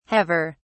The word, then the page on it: haver: British English